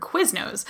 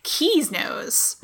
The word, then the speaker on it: Quiznos: Up her own, and you can correct her and she will ignore you and continue to pronounce it her own way, like she does with the word Quiznos, which she pronounces Keysnos.